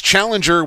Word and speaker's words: Challenger: The way Brad says it, he says it's Challenger without the G.